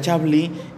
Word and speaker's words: Chablis: Bueno, hay unos vinos que son bien interesantes en blancos, hablando de Francia, que es hablar de Chablis, se pronuncia Chablis y se escribe Chablis en la etiqueta por si lo llegan a encontrar.